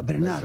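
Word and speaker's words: Bernhardt: Tienes que pronunciar a la francesa, Bernard.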